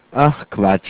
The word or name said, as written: Quatsch